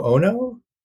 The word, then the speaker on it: Ono: in 2002 and designed by Shintaro Ono. That's how you pronounce it, Japanese designer.